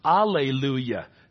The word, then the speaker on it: Alleluia: Sometimes you will see it without the H in the front or the J, pronounced Alleluia.